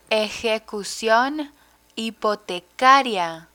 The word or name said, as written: ejecucion hipotecaria